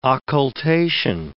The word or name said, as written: occultation